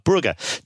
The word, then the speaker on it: Brugge: face Club Brugge in Belgium, or Club Brugge, depending on how you want to pronounce it.